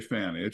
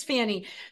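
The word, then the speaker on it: Fani: I say Fannie. It's Fannie.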